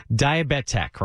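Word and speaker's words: Diabettech: Because I heard you say it's Diabet Tech, right? – Yes, I'm so glad you said it. Like, I'm so glad you said it correctly.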